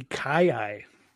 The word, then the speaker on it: kiyi: Is it the Kiyi? The Kiyi? What was it? – That is the Kiyi.